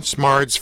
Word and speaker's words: Smardz: First of all, am I saying it right? – Yeah. – It's Polish. – It is. – Karolyn Smardz Frost. – There's no K in it.